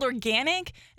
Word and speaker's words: Organyc: It's called Organic. It's actually spelled with a Y, so O-R-G-A-N-Y-C. And they have an entire line of feminine products.